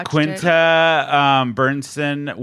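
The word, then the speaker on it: Quinta Brunson: Yeah, Quinta Burnson won for Abbott Elementary.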